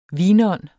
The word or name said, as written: vinånd